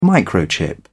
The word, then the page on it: microchip: British English